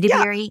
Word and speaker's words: Edebiri: It stars Ayo Edebiri. – Oh, yes. – Am I saying her name right? I don't even know. – Yeah, Edebiri. – Yeah. Ayo Edebiri. Okay.